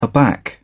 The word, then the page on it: aback: British English